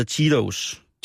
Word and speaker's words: Cheetos: der må du hjælpe mig med at udtale, Cheritos. Det er dem, som laver cheesy, det hedder Cheetos. – Cheetos, ja. Sådan udtaler man det.